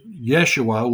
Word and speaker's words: Yeshua: Well, the, the name Jesus is um, uh, pronounced Yeshua.